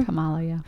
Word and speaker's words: Kamala: Kamala, yeah.